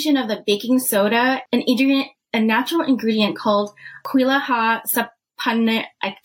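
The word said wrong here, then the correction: Quillaja saponaria: Kuilaha Sapanaria extract and baking soda.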